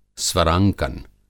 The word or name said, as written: स्वरांकन